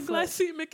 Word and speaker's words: glycemic: Your glycemic index. – Glycemic. It's not a hard C. I was reading that all wrong.